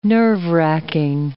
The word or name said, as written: nerve-racking